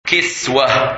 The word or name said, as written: Kiswah